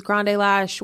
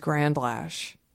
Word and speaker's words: Grande Lash: You said that right. – I used to have, yeah. – Yeah, because some people think it's Grand Lash.